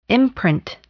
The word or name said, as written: imprint